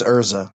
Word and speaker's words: Erza: he's not saying her name right. – It's Erza.